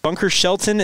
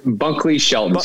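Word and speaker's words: Bunkley-Shelton: Is it Bunker Shelton? Is that what it is? – Bunkley Shelton.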